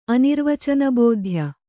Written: ಅನಿರ್ವಚನಬೋಧ್ಯ

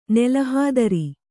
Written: ನೆಲಹಾದರಿ

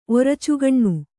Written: ಒರಚುಗಣ್ಣು

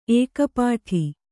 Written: ಏಕಪೀಠ

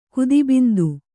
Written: ಕುದಿಬಿಂದು

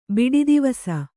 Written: ಬಿಡಿದಿವಸ

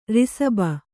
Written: ಋಷಭ

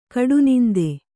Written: ಕಡುನಿಂದೆ

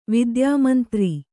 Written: ವಿದ್ಯಾಮಂತ್ರಿ

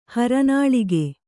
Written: ಹರನಾಳಿಗೆ

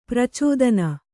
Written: ಪ್ರಚೋದನೆ

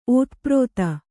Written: ಓತಪ್ರೋತ

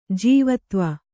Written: ಜೀವತ್ವ

